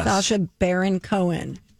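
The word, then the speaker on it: Sacha Baron Cohen: A Sasha Baron Cohen. Is that his name? – Yes. – Sasha Baron Cohen.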